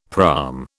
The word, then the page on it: praam: British English